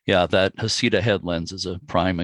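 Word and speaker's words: Heceta: They pronounce it Hisseta.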